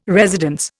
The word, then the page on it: residence: Indian